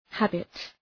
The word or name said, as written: habit